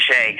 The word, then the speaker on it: Chelly: Like for Sean Martin and the Navajo, Canyon de Chelly, Chelly, but I learned it's pronounced Canyon de Chelly.